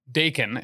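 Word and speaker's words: Daken: Like, I always pronounce his name right. Dakin? – Dakin is how I've said it.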